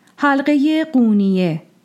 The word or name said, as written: Qūniyeh